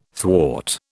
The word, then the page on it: thwart: British English